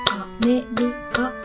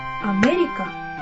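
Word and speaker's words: America: アメリカという言葉を日本人がカタカナで発音するとアメリカという 4 拍になります。これは母音が 4 つあるからです。一方、これをアメリカ人が発音するとアメリカというように、目だけにアクセントを置いたたった一拍で済んでしまいます。アとリカは、その前後におまけでくっつけていくだけという感じです。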